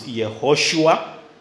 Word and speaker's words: Yehoshua: The Hebrew pronunciation or spelling is Yehoshua or Yeshua.